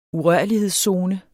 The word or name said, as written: urørlighedszone